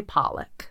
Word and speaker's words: Pollock: I feel like I would say Pollock. – P-O-L-L-O-C-K. – That's how I would pronounce that word. – Pollock. Okay, great. – I'm no pronunciation, but I would say Pollock.